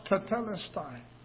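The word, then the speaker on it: tetelestai (τετέλεσται): It's pronounced tetelestai. I studied that word. That word translated, it is finished, tetelestai, is a banking term.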